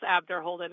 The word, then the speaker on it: Abderholden: It's Abderholden. It's phonetic.